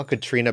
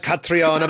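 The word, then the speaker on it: Caitríona: They're like, and now Katrina Balfe. I'm like, that's how you say that? It's not like Catriona Balfe. Like, I'm like, it's just Katrina Balfe.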